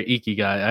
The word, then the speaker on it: ikigai: Dive deep into your ikigai.